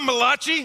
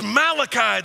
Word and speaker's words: Malachi: It's not Malachi, I'm not an Italian prophet. It's Malachi, that's who it is.